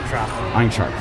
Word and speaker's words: Eintracht: The last episode I watched kind of was focusing on the Bundesliga and the German teams like Iron Frankfurt. Did I pronounce that right? – Eintracht Frankfurt. – Eintracht.